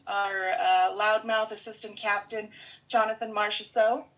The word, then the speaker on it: Marchessault: Marchessault.